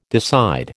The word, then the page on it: decide: American English